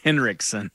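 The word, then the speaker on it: Henriksen: Did I say it wrong? – It's Hendrickson.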